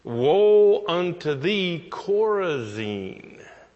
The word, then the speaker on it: Chorazin: It's spelled C-H-O-R-A-Z-I-N, Chorazin.